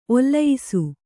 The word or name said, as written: ಒಲಿಯಿಸು